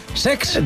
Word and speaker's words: Cesc: El Como de Cesc Fàbregas, que se estrenó... – Lo he dicho mal, es Sex. – De Chex. De Hex Fàbregas, que se estrenó en la competición, ganó 4-1 al Leipzig en otro partidazo.